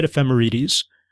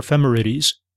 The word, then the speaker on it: ephemerides: I said ephemerides. Or ephemerides, and it's really awkward to say.